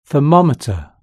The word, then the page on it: thermometer: British English